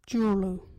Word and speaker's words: Juurlu: local Wajiri word is Jurlo. What is Jurlo? Jurlo is salt. Jurlo. You can use Jurlo in this sentence. You got any Jurlo? Jurlo is spelled J-U-U-R-L-U.